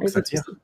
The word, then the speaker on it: Xavier: Mais euh, en tout cas, Chico Xavier, euh, Xavier, excuse-moi, je le prononce mal, Chico Xavier. – mon portugais est très mauvais.